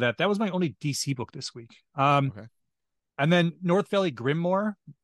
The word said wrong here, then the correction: grimoire: Grimoire, or I'm not sure how you pronounce it. – Grimoire.